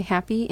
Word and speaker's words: HAPI: And I believe the farmers, it's spelled H-A-P-I, Happy, and it's an acronym for something.